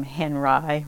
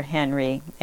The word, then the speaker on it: Henri: Some people pronounce his name Henri, Robert Henri or Henry.